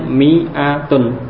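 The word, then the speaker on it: mi'atun (مائة): Tulisannya memang ada alifnya ya. Hmm. Oh. Tapi ini membacanya mi'atun. Jadi alifnya tidak dibaca. Pada lafat mi'atun ada penulisan alif sesudah mim tapi itu tidak dibaca. Jadi membacanya mi'atun.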